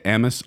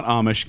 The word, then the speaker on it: Amis: But by the way, that vocalization at the beginning and throughout the song is actually I don't know how to say it, Amis. It's not Amish, Kent.